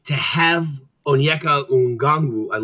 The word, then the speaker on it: Okongwu: Onyeka Okongwu. – That was really good. That was actually right. No, you got it. Okongwu. Without the N. Okongwu.